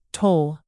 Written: toll

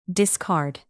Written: discard

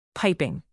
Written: piping

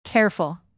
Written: careful

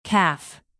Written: calf

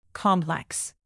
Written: complex

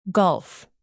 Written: golf

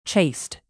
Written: chased

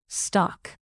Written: stock

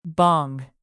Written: bong

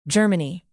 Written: germany

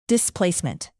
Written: displacement

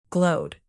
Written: glowed

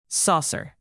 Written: saucer

